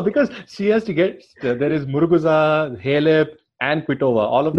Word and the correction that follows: Muguruza: No, Muguruza, Oh, Muguruza, no? – Yeah, she's the defending champion, dude. Give her some respect. – She's actually pronounced Muguruza, I think.